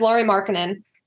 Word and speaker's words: Lauri Markkanen: I believe it's Lauri Markkanen. – Yeah, it is Lauri Markkanen. It's spelled the exact same way as it sounds.